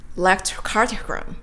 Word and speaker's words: electrocardiogram: The EKG or the electrocardiogram, and this is electrocardiogram.